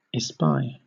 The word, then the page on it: espy: British English